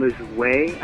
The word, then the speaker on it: Lajoie: His name is John Lajoie, I think that's pronounced. It's J-O-N.